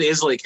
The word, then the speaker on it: Islay: Well, how do you pronounce it correctly? – Islay. – Where does that come from? Is that? – That's just how they pronounce it. – There you go. – Scottish word. So okay, that's what I was trying to see. It's Scottish.